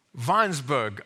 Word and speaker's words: Weinsberg: It's a story about a German town, the siege of that German town of Weinsberg. I'm sure Horst Schulze will correct me when pronunciation. Whenever you see W, it's a V.